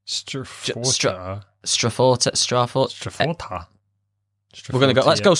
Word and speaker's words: STR4TA: How are we pronouncing this? Strata? – It's Strata, but they've done that.